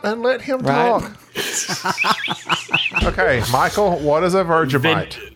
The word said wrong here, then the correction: Vegemite: Vegemite. – Thank you. – Vegemite. – My first Vegemite. – Wife. – So Gabby said it right.